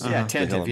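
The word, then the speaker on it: Tantive: Because some of the Tantivy 4, as they did pronounce it the correct way today. Ask Pablo, ask the radio drama. – That's Tantivy.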